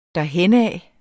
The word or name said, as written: derhenad